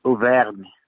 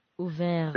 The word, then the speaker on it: Auvergne: Is it Avrenier? How do you pronounce it? Sorry, my French is... – The place is called Charroux. – Charroux? – The little town's name. Yeah, and the area is in Auvergne.